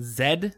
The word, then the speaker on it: Z: Zed? – Yeah. – Hmm. – Which is the British pronunciation of the letter Z.